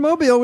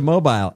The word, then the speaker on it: Mobile: We went up to Mobile. It's Mobile. – I know. – Or actually, it's Mobile. Isn't Mobile. – It, isn't the accent is on the eel? – The accent is on the right part.